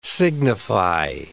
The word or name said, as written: signify